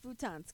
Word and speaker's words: futon: Hey, you know what's a fun fact about futons, okay? – Yeah? – It rhymes with croutons.